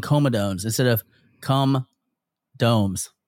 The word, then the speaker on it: comedones: Comedones.